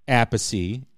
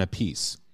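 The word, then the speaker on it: Appice: Well, no, they do pronounce it differently because Vinny goes by Vinny Apice and Carmine goes by Carmen Apice.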